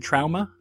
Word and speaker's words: trauma: Do you go to the hospital because you're suffering from head trauma? Is that how they say it? No, it's trauma. Trauma.